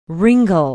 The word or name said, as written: Ringle